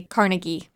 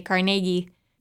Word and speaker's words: Carnegie: Everywhere else I've lived, they say Carnegie, but here they say Carnegie, with a bigger emphasis on the middle syllable.